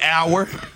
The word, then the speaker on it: hour: This was another one that threw me hour. Like time. – Yeah. The H is silent. – Exactly. H-O-U-R, the H is silent. Doubt. – But wait, I'm sorry. Mm-hmm. O-U-R is also hour.